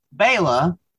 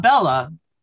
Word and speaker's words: Bela: So then Jenny is told by Bela. Not Bella.